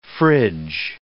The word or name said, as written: fridge